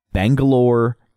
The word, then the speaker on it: Bangalore: Bangalore, India.